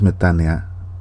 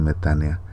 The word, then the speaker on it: metanoia: La palabra es metánea, se escribe metanoia, se pronuncia metánea, que significa cambio de actitud.